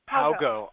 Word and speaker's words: Haugo: It's Haugo. Like, how goes it? – Haugo.